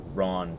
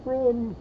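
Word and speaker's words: Rawne: And Gaunt goes missing for a bit, and Ron takes command. – Ron. – Yeah. – It's always a Ron. – It's R-A-W-N-E. So they spell it.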